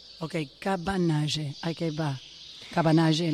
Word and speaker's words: Cabanagem: Gracias por el paréntesis histórico de entender que fue el cómo se pronuncia, perdónante. – Cabanagem, em decorrência. Cabanagem. Espera, decilo mais despacio.